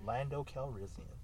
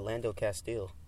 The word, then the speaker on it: Calrissian: Lando Calrissian. – That's his name? – Yeah. – Oh, I always said it wrong. – What? – I always said Lando Castile.